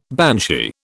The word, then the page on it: banshee: British English